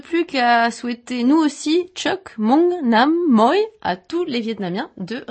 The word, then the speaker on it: Chúc mừng năm mới: Ça se prononce ainsi. – Chúc mừng năm mới.